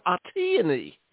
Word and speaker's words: Atyani: Yeah, my name, you're doing really well, and even better is Atyani, like a peony, Atyani. – Wow. I had my syllables all wrong. Atyani.